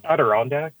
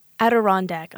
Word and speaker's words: Adirondack: Yeah, and since we already mentioned, how do you say the album name again? – Oh, Adirondack. – Adirondack. Okay, I had it wrong from the start.